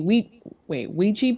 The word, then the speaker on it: Ouija: But when I was younger, there was these boys down the block who they had a Ouija board.